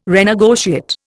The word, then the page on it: renegotiate: Indian